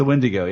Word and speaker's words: Wendigo: Was it Wendigo, or how do you pronounce it? – It's the Wendigo, yeah.